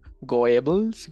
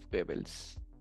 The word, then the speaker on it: Goebbels: Que la germana tio pronuncia isso, né? Goebbels. Joseph Goebbels. Ah, estas O com umlauf, né? Isso, né? Es Goebbels.